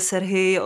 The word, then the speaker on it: Sergej: Je to rusky mluvený film, odehrává se v Rusku, ale zaškatulkovat někam Sergeje Loznicu prostě nejde. Vy vyslovujete Sergej, on se vyslovuje Sergej,